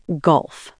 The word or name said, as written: gulf